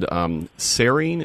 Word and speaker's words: serine: You know, throughout the Toxic Puzzle movie, they talk about the amino acid serine.